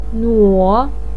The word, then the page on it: nuo: 3